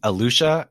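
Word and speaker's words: Eleuthera: I think it's pronounced Alusha.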